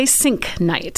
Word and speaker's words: Sienknecht: We say Sienknecht.